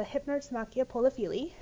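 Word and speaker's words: Hypnerotomachia Poliphili: So again, that book title is The Hypnotsumachia Polyphili. That is the pronunciation that I settled on, since there isn't 100% correct way to say it. But that's kind of what I see is most agreed upon.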